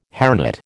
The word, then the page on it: hairnet: American English